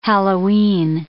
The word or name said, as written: Halloween